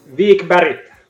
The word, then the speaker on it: Wikberg: Wikberg.